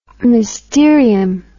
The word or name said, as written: mysterium